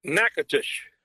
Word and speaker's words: Nacogdoches: I know that you're very busy videoing cattle and procuring cattle for the sale, but I gotta ask is it pronounced Nacatish or Nacogdoches? – Nacatish. – So, how many people actually pronounce that wrong? Everybody? – Lots of folks.